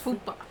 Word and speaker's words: FUPA: That's how she's pronounced it. It's pronounced FUPA.